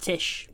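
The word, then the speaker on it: Tisch: and studied dramatic writing at the Titch School of the Arts. – It's pronounced Tish. – Tish? – Mm-hmm. – What did I say? – Titch. – Did I say Titch? I meant to say Tish.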